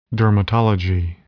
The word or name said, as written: dermatology